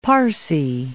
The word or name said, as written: Parsi